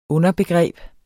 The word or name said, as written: underbegreb